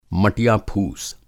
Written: मटिया-फूस

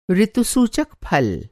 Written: ऋतुसूचक

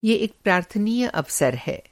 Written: प्रार्थनीय

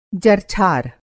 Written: जरछार

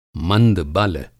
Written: मंदबल

